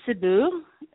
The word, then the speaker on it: Cebu: You're located in Cebu, am I pronouncing that correctly? – Yes, I'm in Cebu.